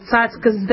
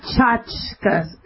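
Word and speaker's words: tchotchke: it's tzatzka, not tchotchka. You guys mispronounce that. You call, like, little toys or gadgets tchotch. I don't understand that. It's tzatzkas. They're called tzatzkas, like a T-S, like with a tzadi.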